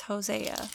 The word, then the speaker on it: Hosea: See, in mine it says O-C, O-S-E-E. – Oh. Oh. Well, that's different. I've been saying it wrong as I'm reading it. Hosea. I'm not functioning correctly. It's Hosea.